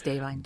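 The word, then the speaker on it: Da'Vine: Davine, is that how you say it? Yes, it's Davine.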